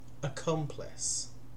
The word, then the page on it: accomplice: British English